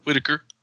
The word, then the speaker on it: Whitaker: Whitaker.